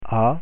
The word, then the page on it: a: French